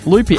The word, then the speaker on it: Lupi: Did you say his name was Lupi? – Lupi, L-U-P-I.